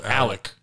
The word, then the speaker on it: Alex: It was also spelled Alex, and he pronounced it Alec.